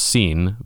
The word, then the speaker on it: sin: The word sin in Spanish, it's pronounced sin, but it means without.